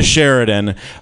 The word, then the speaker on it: Sheraton: I'm just going to go where someone, like, you can't find me at the Sheraton. No, not the one at the airport, the one downtown. The one on Sutter Street. Yeah, rhymes with Clarendon, but with a sh.